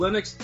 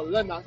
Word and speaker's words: Lennox: You know, I'm always thinking, it's like, isn't this supposed to be Lennox? And Lennox is like Lennox. And I'm like... – Because the Americans don't like to pronounce the second N.